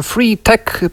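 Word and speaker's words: 3TEK: Po pierwsze, jest sobie taka mała firma z Danii, która nazywa się Free Tech, pisze się to 3, cyfra 3 i TEK, nawet nie Tech.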